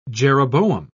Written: jeroboam

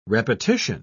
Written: repetition

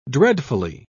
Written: dreadfully

